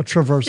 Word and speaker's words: traversal: The one that drives me nuts in this list is the path transversal one. – Wait, you know, how did you say it? Hold on. Back up. Transversal. – A traversal.